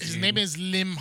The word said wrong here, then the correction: Limahl: I don't know. How do you pronounce it? It's Limahl. – Limahl.